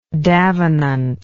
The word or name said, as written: davenant